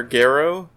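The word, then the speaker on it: Gero: but apparently his name's pronounced Dr. Garrow.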